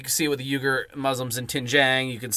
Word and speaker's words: Uyghur: It's pronounced Uyghur. We were corrected,